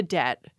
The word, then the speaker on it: debt: Therefore, they put the B back into debt and introduced this silent letter into the spelling.